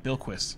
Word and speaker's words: Bilquis: I'm like, how did you say it before? – Yeah, Bilquis. – Bilquis.